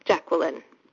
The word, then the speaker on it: Jacqueline: and I pronounce it with three syllables, Jacqueline.